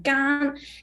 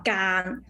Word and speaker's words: 間: But this character, normally we will see is 中間的間, it will pronounce the first tone, gan. And also, yes, the gan also have two pronunciations. The other one is three tone, is gan.